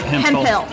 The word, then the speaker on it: Hempel: And did I say your name right? – Hempel. – Okay, yep. Got it. So no F sound in there.